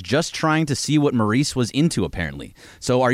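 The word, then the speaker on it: Maryse: So you obviously love the WWE, and you're gonna have to help me out with how to say this name. But well, the Miz and Maurice. Is that how? – Yeah, Maurice.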